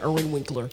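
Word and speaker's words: Irwin Winkler: Yeah, Erwin Winkler.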